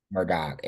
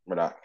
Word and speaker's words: Murdaugh: Murdoch. It's like a K. It's Okay, Murdaugh.